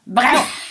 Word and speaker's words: Breuer: Breuer.